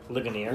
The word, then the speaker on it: Ligonier: Anyway, so actually, how do you pronounce the website? – Ligonier. – Ligonier.